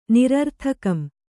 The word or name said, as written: ನಿರರ್ಥಕಂ